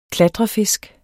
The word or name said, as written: klatrefisk